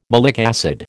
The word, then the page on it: malic acid: American English